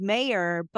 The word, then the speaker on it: Mayer: And then my last name, which sounds so simple, is Mayer, but it's spelled like Oscar Mayer.